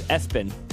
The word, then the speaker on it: ESPN: There's another name that's spelled like ESPN, but they pronounce their baby as Espen.